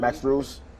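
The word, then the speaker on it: Strus: That's how you say his name? Max Shrews. – Max Shrews.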